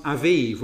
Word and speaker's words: Aviv: The month Aviv, we get it in scripture as Abib, but you pronounce the B as a V, Aviv.